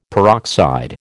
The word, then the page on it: peroxide: American English